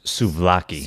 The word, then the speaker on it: souvlaki: Yeah, and I mean, the way you pronounced it, I think it's pronounced souvlaki.